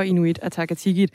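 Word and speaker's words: Inuit Ataqatigiit: Medlem af Folketinget for Inuit Atakatikit. Er det en, en godkendt udtale? Ja, særligt den sidste, den var rigtig fin.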